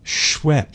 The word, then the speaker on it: Schweppes: Schweppes. – Schweppes. – S-C-H. Schweppes. Rhymes with Peps.